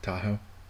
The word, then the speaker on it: Tahoe: Given their name, you can probably guess they're recorded in the Sierra Mountains of California, somewhere between Lake Tahoe and Yosemite National Park. – Tahoe.